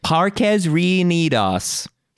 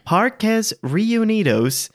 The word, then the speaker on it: Parques Reunidos: Parques Reunidos. As I watched a video so I could pronounce it right for one of my recent videos. – Well, you Google how to pronounce something, it's like Parques Reunidos.